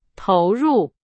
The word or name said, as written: 投入